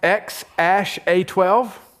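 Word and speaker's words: X Æ A-12: Elon Musk, did he name his child that, which is pronounced X-Ash-A-12? Yes.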